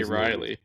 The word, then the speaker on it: Reilly: Ben Ridley, really, whatever. – I say Riley. That's Riley. How I've always known it, Ben Riley. Riley.